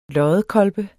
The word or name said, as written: loddekolbe